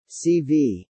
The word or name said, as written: CV